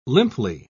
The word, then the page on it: limply: British English